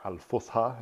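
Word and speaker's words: Al-Fusha (الفصحى): And what he means by that is that there's a language that they call modern standard Arabic, or Al-Fusha, as they pronounce it in Arabic, which it's not like a dead language, but nobody speaks it nowadays.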